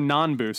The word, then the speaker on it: Nanbu: Nabu, yep. Mr. Nabu. Let me see if I have the right name here. Mr. Nanbu, sorry.